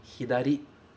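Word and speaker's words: hidari (左): E a pronúncia dele é hidari.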